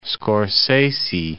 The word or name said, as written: Scorsese